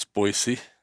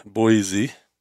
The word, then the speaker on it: Boise: As I remember when I lived in Boise, and that's how you pronounce Boise. It's not Boise.